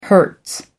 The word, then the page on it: hertz: American English